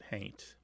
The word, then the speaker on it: haint: Somebody might spell it haunt, but pronounce it haint.